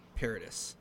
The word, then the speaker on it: Paradis: I think you say his name, Matt Paradis. – Paradis, okay. – Paradis. That is how I'm pretty certain his name is pronounced.